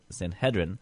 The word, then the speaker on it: Sanhedrin: The buzzword I have for you today, I hear it pronounced a couple different ways. I always call it the Sanhedrin. Some people call it the Sanhedrin.